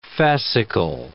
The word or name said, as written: fascicle